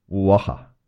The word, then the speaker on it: Oaxaca: Oaxaca? – Oaxaca.